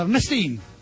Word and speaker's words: Ernestine: in inglese come si pronunciava Ernestina? – Ernestine.